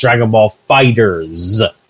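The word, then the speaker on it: Dragon Ball FighterZ: But since I've been back, I also got I buckled and I got Dragon Ball Fighter Z, or Dragon Ball Fighter Z, as it's pronounced. Namco Bandai officially say it's pronounced Dragon Ball Fighters. That's how it's supposed to be pronounced.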